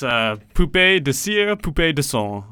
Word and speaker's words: Poupée de Cire, Poupée de Son: Anyway, next week we're going to be back with a song I can't pronounce. – It's Poupée de Cire, Poupée de Son.